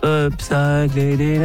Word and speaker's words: upcycling: Upcycling again. – Ouais, bien. Upcycling. – Du vélo sans selle? – Du vélo. Ah oui. – Upcycling. – Donc ça se prononce upcycling.